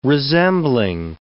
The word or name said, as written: resembling